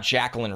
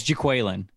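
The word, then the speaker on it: Jaquelin: 29, I know it's not Jacqueline Roy. I got to pull up his pronunciation. I just had it. Because we this is one of those guys we check. – It's Jaqueline.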